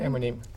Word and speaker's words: Emunim: In the modern world, from Christian identity to Gush Emunin. Did I say that right? – Emunim. – Thank you.